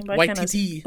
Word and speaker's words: Waititi: I can't say his name. – Waititi. – Waititi. I want to say Waikiki for some reason.